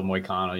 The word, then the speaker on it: Moicano: Next fight, we got Turner and how do you say it? Is it Moicano? – Renato Moicano.